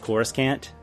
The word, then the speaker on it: Coruscant: It's pronounced Coruscant.